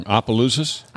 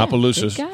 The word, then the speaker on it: Opelousas: Opelousas. – Yeah, Opelousas.